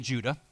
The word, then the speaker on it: Judah: So Jude, or better translated Judah, based on his pronunciation. So it's we call it Jude because that's but in the pronunciation in both Greek and Hebrew, it would have been Judah, okay, is the author of the book, and he's Jesus' brother.